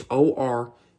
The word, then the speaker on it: Oruwariye: It is Oruwariye.